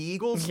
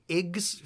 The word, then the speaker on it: Eagles: Yeah, speaking of sports, so you going to get yourself a water ice and watch the Eagles? – Yeah. Yeah. I'm making a cheesesteak stromboli. – Oh, good. Are you an Iggs fan? Am I pronouncing it right? Yeah. The Iggs? – No, it's Eagles.